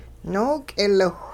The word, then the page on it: nook'iliwh: Hupa